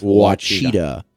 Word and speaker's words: Ouachita: I'm going with Ouachita.